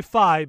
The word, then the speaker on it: Fi: I say Phi because they have said that it is supposed to be like hi-fi.